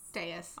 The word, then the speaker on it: dais: The king is up on the I can never say this word. – Dais. – Oh, okay, I do say it right.